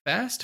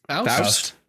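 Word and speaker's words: Faist: How do you say his name? Mike Fast? – Faust? – Faust. – Oh, Faust.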